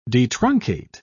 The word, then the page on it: detruncate: British English